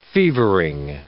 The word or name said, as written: fevering